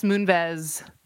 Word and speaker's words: Moonves: And I you know, Les Moonves, is that how you pronounce his last name? – Yes.